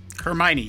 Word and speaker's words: Hermione: Am I saying that right? – Hermione, yes.